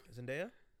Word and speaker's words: Zendaya: Zendaya was sick. She gave her How'd you say her name? – Zendaya.